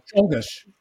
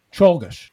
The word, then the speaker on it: Czolgosz: Is it Leon Czolgosz or something like that? – Czolgosz. – Oh, okay. Go ahead. – I guess you could say Czolgosz. Czolgosz.